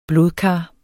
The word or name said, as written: blodkar